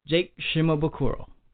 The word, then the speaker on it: Shimabukuro: Jake Shimabukuro.